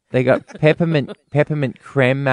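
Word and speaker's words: crème: I don't know how to say this word. C-R-E-M-E, with an accent acute on the E, the first creme. – E. – Creme. – So that's that makes it creme, does it? – Creme.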